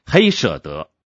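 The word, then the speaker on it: hesed: 这个字的读音类似黑舍德，它最基本的意义是爱，可是不是一般的爱，乃是爱加上信实、坚定、不变而成的。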